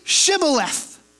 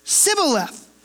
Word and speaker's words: Shibboleth: So in verse 6 it says, Then say Shibboleth. And he said, Sibboleth, for he could not pronounce it right.